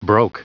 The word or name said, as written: broke